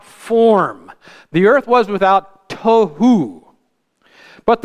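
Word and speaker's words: Tohu (תהו): The word form in Hebrew, I'm going to spell it this way in English so that we can pronounce it. T-O-H-O-O. Tohu. Without form. Tohu.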